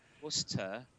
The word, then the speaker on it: Worcester: You say wuss, as in Mike's a wuss. – Oh! – Whoa, fighting words. Zing. – Google smack. – Google. – Worcester.